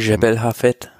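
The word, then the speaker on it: Jebel Hafeet: Jebel Hafet äh, hochkommen. Jebel Hafet.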